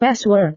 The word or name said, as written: password